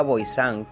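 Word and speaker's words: Voisin: Catherine Montboisin o Montboisin, nacida en Des Halles, 1640, 22 de febrero hasta 1680, conocida como La Boisín o La Boisán, que es la pronunciación de este apellido, fue una supuesta bruja, adivina y envenenadora profesional francesa.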